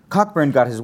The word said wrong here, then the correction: Cockburn: It's pronounced Coburn.